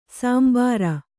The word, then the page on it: ಶಾಂಬರ: Kannada